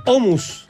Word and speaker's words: hummus: Quisiera referir que el hummus se pronuncia hummus.